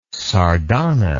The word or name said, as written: sardana